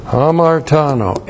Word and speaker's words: hamartano: Hamartano, H-A-M-A-R-T-A-N, long O, hamartano.